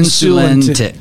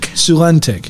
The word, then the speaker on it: Sulentic: Is any part of that name right? – Tegan Sulentic. – Sulentic. – Yep. – Put the accent on the wrong syllable, too.